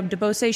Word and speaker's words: DeBose: No, it's Ariana DeBose. I'm not sure how to say it. – Probably DeBose. – Yeah, DeBose.